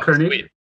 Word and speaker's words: Koenig: And I'm curious if you can tell us a little bit more about how Koenig, is that how you say it? – Yes, Carl Koenig.